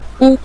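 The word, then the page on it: gu: tone 5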